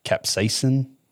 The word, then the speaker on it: capsaicin: it's C-A-P-S-A-I-C-I-N. Capsaicin? – Mm-hmm.